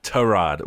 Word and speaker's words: Tyrod: John, I noticed you're pronouncing it Tyrod, which is how he wants it, right? Like, isn't that the right way? – Exactly. Yes, indeed.